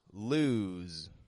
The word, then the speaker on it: lose: So when we pronounce the word lose, we're making sure that the S sounds like a Z.